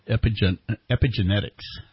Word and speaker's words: epigenetics: It's called epigenetics. Did I pronounce it right? – Yeah, great job.